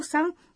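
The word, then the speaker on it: San (山): No entanto, a pronúncia muda para... – San. – Quando essa palavra é colocada depois do nome de algumas montanhas.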